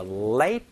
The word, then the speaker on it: late: I am sorry for the late reply. 여기에서 발음을 살짝 주의하실 부분은요, late 이라고 얘기를 할 때 그 늦은은 여러분 치경이라고 얘기를 해서 윗니 뒤쪽을 꾹 눌러주시는 late이고요, reply 라고 얘기를 할 때는 입이 모아지는 reply, reply에서 L 소리랑 R 소리를 조금 구분해서 얘기를 하시면 좋습니다.